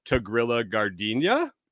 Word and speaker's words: Tigrilla Gardenia: And so they pronounce it the Italian way, which is Tigrilla Gardenia, not the ya. And then the Americans just say, you know, Tigrilla, because it's easier.